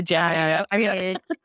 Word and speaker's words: djembe: the djembe? – Oh, the djembe.